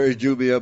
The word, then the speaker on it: Idumea: Or Idumea, but yeah. I pronounce it Idumea.